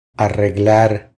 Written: Arreglar

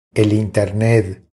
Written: El internet

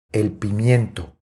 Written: El pimiento

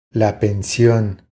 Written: La pension

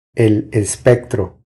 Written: El espectro

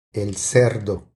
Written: El cerdo

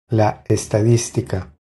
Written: La estadistica

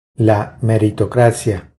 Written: La meritocracia

